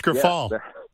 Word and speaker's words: Grifol: Pedro Grifol. – Grifol. – Other than that, it's Grifol.